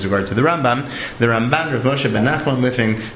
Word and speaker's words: Ramban: And not to be confused with Rambam himself, Rav Moshe ben Nachman, pronounced at least by Ashkenazim as Ramban, in contradistinction to Ashkenazim's Rambam, as opposed to Sfaradim, who pronounced both Mila Ra, in other words, with the accent on the second syllable, Rambam and Ramban, which can, for me personally, can sometimes be confusing.